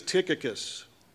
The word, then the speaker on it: Tychicus: This guy's name is Tychicus. This is what CF told me. This is how you pronounce it.